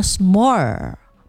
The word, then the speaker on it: semur: Jadi bacanya tuh adalah smore, yang berarti metode merebus daging secara perlahan tapi dalam waktu yang lama supaya bumbunya tuh meresap secara sempurna.